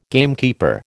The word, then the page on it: gamekeeper: American English